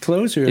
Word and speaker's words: Clojure: Is it Clojure? How would you say it? Clojure as a coding language? – Clojure.